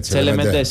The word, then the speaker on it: Τσελεμεντέ: Στο νησί του Τσελεμέντε θα έχει φαγητό. – Τσελεμεντέ, συγγνώμη. – Τσελεμεντέ, γαλλικά θα το τονίζεις.